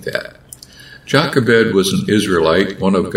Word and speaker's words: Jochebed: In the Hebrew pronunciation, the J takes on a wide sound, Jochebed.